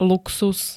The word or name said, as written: luxus